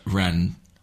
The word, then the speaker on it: Rennes: Who he worked with at Rennes. – Rennes. Is that not how it's pronounced? Rennes. – I don't know. Rennes. Oh, no, Rennes.